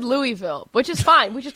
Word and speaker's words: Louisville: Plus, he said this S is silent. Didn't I say? I swear I said Louisville. – But they do, as a Southerner, they say Louisville.